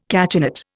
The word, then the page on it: cachinnate: Indian